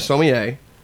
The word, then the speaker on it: sommelier: And you actually are, I always mispronounce, or I can't remember, but you're a sommelier. – Mm-hmm. – But it's bigger than that. – Perfect pronunciation. Yeah.